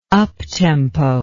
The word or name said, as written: uptempo